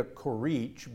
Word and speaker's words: Corrici: Her name is Claudia Corrich, but if you look for her name, it's going to be spelled Corrici, with an I on the end of it, right? – Right. – Okay, but you don't pronounce the I.